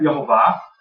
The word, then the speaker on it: Yehovah: I pronounce it Yehovah, because that's the way it's vocalized in the Masoretic manuscripts, and of course, I'm not going to get into that.